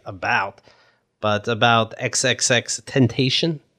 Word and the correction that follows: Tentacion: Tentacion. – Is that really how you say it? – Mm-hmm.